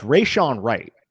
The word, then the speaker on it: Rejzohn: Yeah, so it's Rashawn Wright, but it's spelled R-E-J-Z-O-H-N. But I believe the pronunciation is just Rashawn Wright.